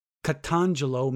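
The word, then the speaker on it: Katangelo (καταγγέλλω): is the Greek word katangelo. Katangelo is actually the proper pronunciation.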